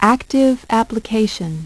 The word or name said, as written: active application